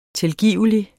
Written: tilgivelig

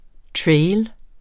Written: trail

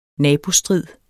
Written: nabostrid

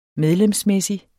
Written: medlemsmæssig

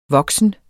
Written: voksen

